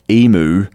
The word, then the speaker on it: emu: And for our American listeners who have a tendency to pronounce that funny-looking Aussie bird, emu, it is emu for future reference.